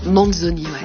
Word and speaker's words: Manzoni: Manzoni, ouais. – Manzoni, c'est bien dit comme ça ? – Ouais.